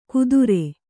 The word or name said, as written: ಕುದುರೆ